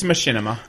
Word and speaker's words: Machinima: Do any of you guys keep track of, I'm going to butcher the name, it's Machinima or Machinima? – It's Machinima.